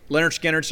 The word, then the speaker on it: Lynyrd Skynyrd: Now, pronounced Lynyrd Skynyrd, I had that on cassette.